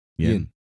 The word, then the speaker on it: Yeun: Steven Yeun. Yeun. – Yeun? – Yeun.